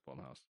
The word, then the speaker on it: Blumhouse: Is it Blumhouse? Blumhouse. – I'm pretty sure it's Blumhouse. – I hate that. – I don't know. You can say Blum if you want, then. Not like we haven't changed pronunciations before. – TV. Now I'm going to go with Blumhouse. You know? I mean, that's how it sounds. I guess when you read it, that's how it should sound.